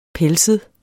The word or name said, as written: pelset